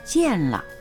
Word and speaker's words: 建了: 大庭城と書いて大庭城が建っていましたは、建了と言います。漢字、建てる、終了の了と書きます。